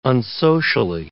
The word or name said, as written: unsocially